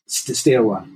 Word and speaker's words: Steaua: Stewa, yeah. Think of it as in stair climbing, a set of stairs, and W at the end, Stewa.